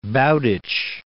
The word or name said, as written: Bowditch